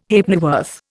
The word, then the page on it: Halfpennyworth: Indian